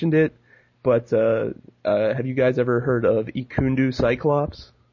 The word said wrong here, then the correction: Ekundu: It's Ekundu Cyclops.